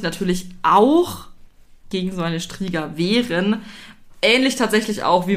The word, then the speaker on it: Striga: Striga. Ja, kannst du das aussprechen? Du kannst ja so ein bisschen. – Äh, ja, Striga wäre es.